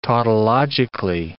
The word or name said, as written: tautologically